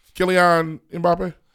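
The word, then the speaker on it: Kylian Mbappé: Oh, am I saying this right? Kylian Mbappe. – You saying it correctly? Yes.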